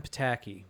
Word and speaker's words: Psaki: So Biden's press secretary, Jen Psaki, which I think that's how you pronounce her name. That's how I've heard it pronounced.